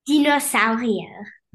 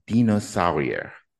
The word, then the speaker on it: Dinosaurier: And I probably pronounced that French, so pronounce it German for me. – Dinosaurier. – Dinosaurier. Got it.